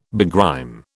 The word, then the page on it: begrime: British English